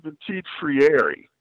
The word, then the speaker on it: Petit Ferrier: I pronounce it Petit Ferrier. I actually pronounce the E as a long E, but between the two of us, I think we're about there, so it's all right.